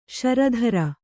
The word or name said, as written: ಷೇರುದಾರ